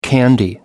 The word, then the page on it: candy: American English